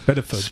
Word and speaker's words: SPDFGH: Spitterfug. – Spitterfug, which is S-P-D-F-G-H, so no vowels there.